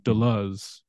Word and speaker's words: Deleuze: The first time you were ever on this podcast, I looked it up exactly how to pronounce Deleuze because we say it in the English tongue, but it's more like Deleuze if you say it in French.